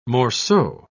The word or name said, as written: morceau